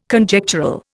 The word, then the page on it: Conjectural: Indian